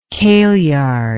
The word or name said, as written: kaleyard